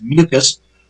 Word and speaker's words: MQS: Behind him, clapping, was Michael Quinn Sullivan, affectionately known to his enemies as MQS, pronounced Mucus,